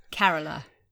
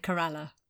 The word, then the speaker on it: Kerala: You said it wrong. Kerala. – That's what I just said. – You said Corella.